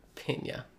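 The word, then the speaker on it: Peña: Michael Pena. He is Pena. – A... – Michael Pena. – Cool. – Gotta say it correctly. – Pena. Happy? – Yeah.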